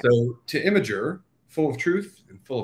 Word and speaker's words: Imgur: Is this pronounced Imgur? Imgur, how do we do that? – I call it Imgur, but okay.